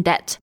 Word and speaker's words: debt: Well, often when people are broke, they are in debt. – 哦、uh,，debt 就是债、欠债哈，d e b t。但这里的 b 呢是不发音的，so you can describe someone as crazy in debt。